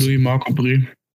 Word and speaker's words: Louis-Marc Aubry: Am Ende war das fast Jacke wie Hose weiß nicht, Louis-Marc Aubry, spricht man, spricht man nicht so aus? Louis-Marc Aubry. Louis-Marc Aubry, wunderbar.